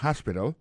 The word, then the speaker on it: hospital: Hospital. Alors, accent tombe sur le premier syllabe là, il y a trois syllabes, puis she likes to visit hospital. Ensuite, elle a écrit H-O-S-P-I-T-A-L, hospital, l'hôpital.